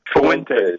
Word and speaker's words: Fuentes: Is that Fente? How do you say it? – Fuentes. – Okay. – Yeah. Fuentes.